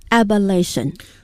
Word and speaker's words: abolition: 词尾不仅发生了变化，而且读音也发生了变化， abolition abolish abolition。 – 大家注意它的拼写 A-B-O-L-I-T-I-O-N A-B-O-L-I-T-I-O-N abolition。